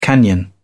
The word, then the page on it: canyon: British English